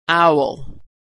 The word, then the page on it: owl: aw